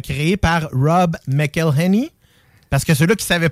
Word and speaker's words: Rob McElhenney: C'est Rob McElhenney.